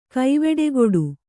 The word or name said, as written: ಕವಡೆಗಟ್ಟು